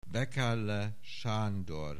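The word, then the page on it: Wekerle Sandor: Hungarian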